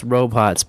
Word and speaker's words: Robhots: it's pronounced Robots, but there's an H in it. Robhots, right? – I'll put them in the show notes, yeah.